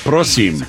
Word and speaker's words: prosím: To the man, cause it's always I forgot you have to emphasize the first syllable. – Prosím. – So like moron. Moron. No, it'd be moron. – Prosím, moron.